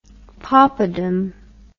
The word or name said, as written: poppadom